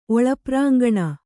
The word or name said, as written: ಒಳಪ್ರಾಂಗಣ